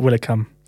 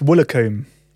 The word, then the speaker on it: Woolacombe: It's not Willicombe. – Sam kept going to Willicombe. – No, it's Woolacombe. – You went to Willicombe. – It's Woolacombe.